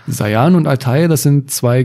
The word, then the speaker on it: Sayan: Du hast zum Beispiel mal im Alleingang die Gebirgszüge des Sayan, wird Sayan ausgesprochen? Sayan, ja.